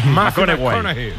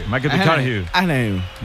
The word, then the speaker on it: McConaughey: Mateo McConaughey. – Sí, sí, sí, sí. Sí, sí. – Cojones, me fichó, le gusta. Hay que ir corrigiendo la pronunciación. – McConaughey. McConaughey.